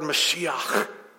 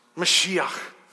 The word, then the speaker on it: Mashiach: It's a Hebrew word we should all know and know how to pronounce and impress our friends at work with. It's the word Mashiach. Mashiach.